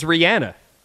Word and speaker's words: Rihanna: So some of Rihanna's fans complained about Jimmy Kimmel pronouncing her name Rihanna. What is the correct pronunciation? – I thought you told me it was Rihanna. – It is. It's Rihanna. It's Rihanna. You're right.